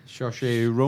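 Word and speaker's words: Saoirse: Shoshé Ronan.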